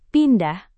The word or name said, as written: pindah